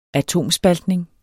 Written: atomspaltning

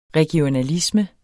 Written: regionalisme

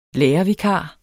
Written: lærervikar

